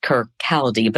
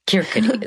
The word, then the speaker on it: Kirkcaldy: So for a single mother in Kirkcaldy, Scotland, typically looked like it's pronounced Kirkcaldy, but I've been corrected by many a Scot that Kirkcaldy is how we say that.